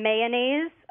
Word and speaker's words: mayonnaise: And then mayonnaise. A lot of people forget that middle syllable and just say mayonnaise.